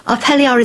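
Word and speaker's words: Up Helly Aa: What's the upheli ar, if I'm pronouncing that correctly? – You are pronouncing it beautifully. Upheli ar is a fire festival.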